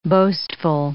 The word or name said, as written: boastful